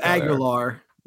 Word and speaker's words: Aguilar: Unless Aguilera. I'm probably pronouncing that one wrong. – Yeah, it's just Aguilar. – Is it Aguilar? – Yeah. You're thinking of Christina.